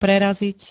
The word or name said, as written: prerazit